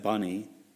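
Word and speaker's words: Bunni: Then it says, Jeshua, Benai, Kadmiel, Shebaniah, Bunai, some people pronounce that bunny, which I think sounds kind of weird, especially Easter week, but, you know, bunny, okay.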